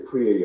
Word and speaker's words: Kriya: And so I started reading it, and he started talking about Kriya Yoga, and he started talking about the benefits of Kriya Yoga and how it's related to, he calls it, it's a quicker way to know God.